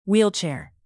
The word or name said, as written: wheelchair